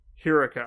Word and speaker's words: Hiruko: So my very, very uninformed Japanese lesson for you is there is no emphasis in Japanese. You pronounce every syllable equally. And the sounds of vowels do not change based on what's around them. – Okay. – Right? So, like, Ru is pronounced like Naruto because it's R-U. – Okay. Got it. Hiruko.